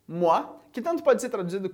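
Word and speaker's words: mois: E como que você leria essa palavra com S do final? Exatamente da mesma maneira, a gente ainda diz moi. O S a gente não pronuncia e moi com S significa mês.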